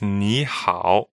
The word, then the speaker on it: ni hao: Yeah, it's ni hao. – Right, which literally means you good. It's third tone, third tone.